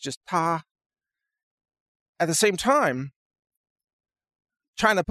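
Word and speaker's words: ta (他): They use an article or a pronoun that's pronounced ta, and you say, ta kai ce, means he's driving his car, or she is driving her car.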